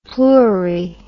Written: pluri

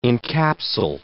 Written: encapsule

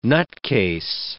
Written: nutcase

